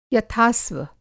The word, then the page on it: यथास्व: Hindi